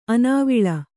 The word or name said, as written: ಆನೆವಾಲು